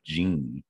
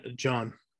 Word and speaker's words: Jean: Coming in at number 8, Gone Till November by Wyclef Jean. Again, don't know that one. And I... – It's pronounced John. – Jean. Wyclef Jean.